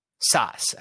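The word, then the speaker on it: Szasz: But I think that there is a connection between what we're talking about and Thomas Schoch. Did I pronounce that right? – I've heard that his last name is supposed to be pronounced as you would say Saskatchewan, so it's Sas. – Sas.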